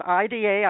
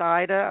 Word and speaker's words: Ida: The nominees are, it's IDA, I've heard it pronounced Ida or Ida.